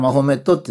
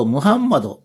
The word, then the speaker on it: ムハンマド: だから日本は昔からマホメットって言ってたって言われてますね。ところができるだけアラビア語の読み方をするとムハンマドっていうのが、まあ非常に正しいということで、最近ではマホメットっていう代わりにムハンマドと言ってますね。